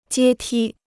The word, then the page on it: 阶梯: jiē tī